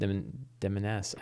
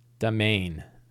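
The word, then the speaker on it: demesne: Twilight Demeness. I don't know how to pronounce that. How do you pronounce that word? Demeness. Domain. Is that how it's pronounced? Domain? Domain.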